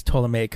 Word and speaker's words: Ptolemaic: Yeah, I think it's Ptolemaic because the P is silent.